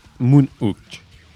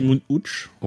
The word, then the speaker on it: Moon Hooch: Alors, euh, je sais pas si je le dis bien, si c'est Moon Hook ou Moon Hooch. – J'ai l'impression que c'est Moon Hooch. Ouais, bon, on va dire euh, Moon Hooch alors.